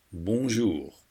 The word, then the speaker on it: bonjour: Bonjour et bienvenue. Bonjour starts with the bon. Repeat, bon. You can slightly hear the end. Jour, meaning day, has the ou sound like in you. Say bonjour. The next word, a, is pronounced like the letter a as in ABC.